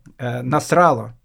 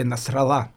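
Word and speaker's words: Насралла: Поэтому для того, чтобы Насрала или Насрала, не знаю, как его там зовут более правильно, Насрала, более правильное ударение, конечно.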